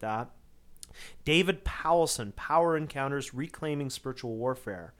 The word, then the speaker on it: Powlison: One is by an author by the name of David Powelson. His name is spelled his last name Powlison, David Powelson.